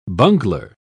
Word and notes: bungler: British English

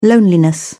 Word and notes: loneliness: British English

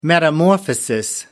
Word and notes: metamorphosis: American English

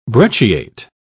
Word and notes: brecciate: British English

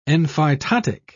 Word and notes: enphytotic: British English

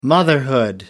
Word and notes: motherhood: American English

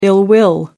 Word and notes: ill-will: American English

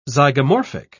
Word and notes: zygomorphic: British English

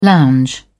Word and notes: lounge: British English

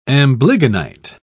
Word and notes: amblygonite: British English

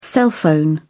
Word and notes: cell phone: British English

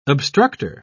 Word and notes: obstructor: British English

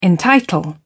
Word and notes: entitle: British English

